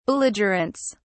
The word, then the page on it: belligerents: British English